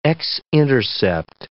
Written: x-intercept

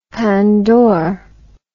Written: pandore

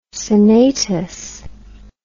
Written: senatus

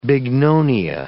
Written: bignonia

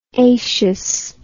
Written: acious

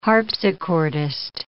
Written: harpsichordist

